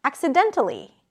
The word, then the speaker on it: accidentally: Accident, accidentally. Now, to pronounce a suffix, we pronounce it with a schwa sound that is represented by the A, an L, and a high E, ali.